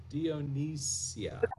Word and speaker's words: Dionisia: Which is his mother, and I won't get into the whole family history, but his mother, Dionysia. – Spell that? – Dionysus, D-I-O-N-I-S-I-A, Dionysia.